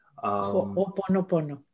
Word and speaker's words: Ho'oponopono: Ho'oponopono. – Ho? – Ho'oponopono. – Am I saying that right? Ho'oponopono? – Yes, perfect.